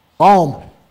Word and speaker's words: Psalm: Psalm. I said it right, Sam.